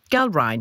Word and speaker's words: Gallrein: Det er sådan, det er ikke helt indlysende, hvordan det skal udtales, men jeg har slået det op. Galrein. G-A-L-L-R-E-I-N.